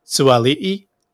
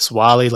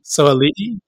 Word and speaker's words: Suali'i: It's to celebrate his culture, his heritage, but it's actually pronounced Joseph Suali'i instead of Swali, like we've been pronouncing. So, can you do that again? I just want to get it familiarized. Suali'i? – Suali'i, yeah.